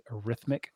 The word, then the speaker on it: arithmetic: Arithmetic mean, that's how you pronounce that, by the way, is arithmetic.